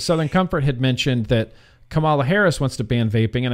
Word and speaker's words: Kamala Harris: Now, if we were going to talk about, I'm going to say her name wrong, Kamala Harris.